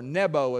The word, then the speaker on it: Nebo: But Moses was allowed to go up on Mount Nebo, or Nebo as they pronounce it over there, to look over into the promised land and to see it from one end to the other.